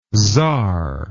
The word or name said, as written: tzar